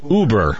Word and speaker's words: Uber: I'm not even sure how to pronounce this. U-B-E-R. Uber.